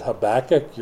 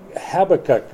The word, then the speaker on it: Habakkuk: Now, a lot of people pronounce that Habakkuk. You're welcome to pronounce it wrong. But Habakkuk was the guy's name.